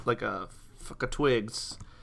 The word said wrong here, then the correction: FKA Twigs: FKA Twigs? – Yeah.